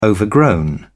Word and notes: overgrown: British English

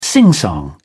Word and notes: singsong: British English

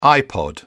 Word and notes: ipod: British English

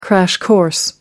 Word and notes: crash course: American English

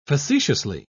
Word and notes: facetiously: British English

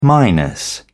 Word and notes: minus: British English

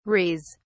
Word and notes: res: British English

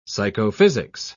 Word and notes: psychophysics: British English

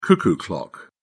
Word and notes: cuckoo clock: British English

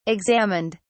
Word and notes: examined: British English

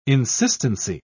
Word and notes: insistency: British English

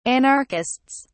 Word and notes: anarchists: British English

